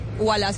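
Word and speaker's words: Ualá: Pero en la práctica ustedes cargan su aplicación, WALA se escribe UALA, abre su cuenta, y empieza a usar WALA.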